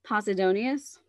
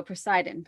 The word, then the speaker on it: Posidonius: And due to, now, in one of Cicero's writings, he mentions an artist called, I'm never going to get this one right, Posidonius. It's not how I keep wanting to pronounce it. I see it and I want to go Poseidon, but that's not right.